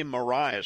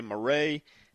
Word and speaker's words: Marais: I say Mariah Street. Now, some people might say Marais. And some people from up north might say Marais, but it's spelled M-A-R-A-I-S.